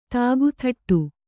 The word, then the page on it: ದಿಕ್ತಟ: diktaṭa